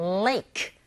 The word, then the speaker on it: lake: 혀가 어디에도 닿으면 안 되고 랄랄랄라 lake.